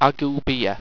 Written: agoubea

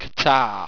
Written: qtaah

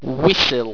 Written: wiccil

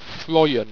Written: froien

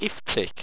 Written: iftic

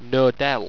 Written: nodel

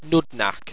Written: pnudnac

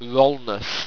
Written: wrolness

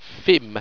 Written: phim

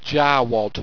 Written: jarwad

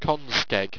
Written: conskeg

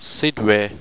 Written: sidrai

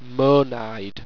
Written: moanide

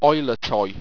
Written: oilettoy

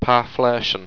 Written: parflertion

